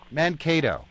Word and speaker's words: Mankato: Am I pronouncing that correct, Minnesota? – Mankato.